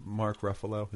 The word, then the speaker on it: Mark Ruffalo: And then what about Mark Ruffalo? Is that how you pronounce it? – Uh-huh.